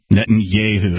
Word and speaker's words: Netanyahu: I believe it's pronounced Yehu. Netanyahu.